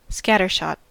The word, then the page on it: scattershot: American English